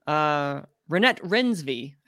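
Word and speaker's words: Renate Reinsve: We have, and I'm so happy to report, Renette Rensvi, I believe is pronounced it, for The Worst Person Renette in the Rensvi.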